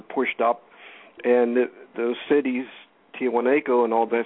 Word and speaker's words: Tiwanaku: Tiwanaku. – Tiwanaku actually did survive the flood.